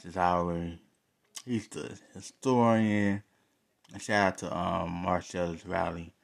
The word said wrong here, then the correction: Wiley: Wiley. I want to say his name right, with a W. Marcellus Wiley.